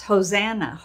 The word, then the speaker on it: Hosanna: And so they're saying, "Save us, O King, Hosanna," which we pronounce Hosanna, Hosanna.